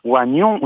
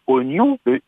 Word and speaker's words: oignon: Au fait, madame, on dit pas oignon, on dit bien oignon. Il ne se prononce pas. On écrit O-I-G-N-O-N, mais on prononce oignon.